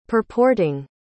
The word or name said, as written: purporting